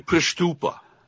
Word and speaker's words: Prystupa: It's Jeffrey Prystupa.